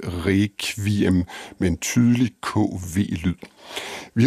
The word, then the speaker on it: requiem: Der siger man requiem med kv, ligesom på dansk, og der er mange andre lyde, der bliver udtalt anderledes.